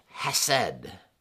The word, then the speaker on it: hesed: But what I want you to know is that this word, how they translate this, is the Hebrew word hesed. Say that with me, that's kind of fun. Hesed. If you didn't spit on your screen, you're not saying it right.